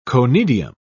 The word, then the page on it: conidium: British English